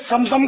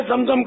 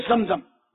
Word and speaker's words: زمزم: سنت کے لفظی معنی طریقہ, جیسے کوئی کہے آب سمسم کا پانی, تو یہ بھی تلفظ کے حساب سے صحیح نہیں ہے نا, فکرہ صحیح نہیں بنتا. آب زمزم کا پانی, آب معنی پانی, آب زمزم یہ کافی ہے.